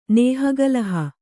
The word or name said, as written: ನೇಹಗಲಹ